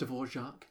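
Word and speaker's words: Dvořák: Our theme music is a Humoresque remix, or the Dvorak Humoresque remix by... – It's pronounced Dvorak. – Is it? – Yeah. – Oh, I didn't know that. – So you know how... – Dvorak? – Yeah, Dvorak.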